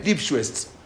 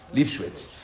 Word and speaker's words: Lipschuetz: El rabino que le dio el get, ahora les voy a decir el, nombre del rabino, este, se llama Rabbi Israel Lipschwitz. Rabbi Israel Lipschwitz. se, escribe, se escribe L-I-P-S-C-H-U-E-T-Z. Él era un rabino importante, no era un rabino L-I-P-S-C-H-U-E-T-Z. Lipschwitz.